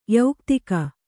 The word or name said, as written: ಯೌಕ್ತಿಕ